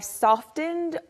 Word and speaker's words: soften: You usually soften the T sound in soften.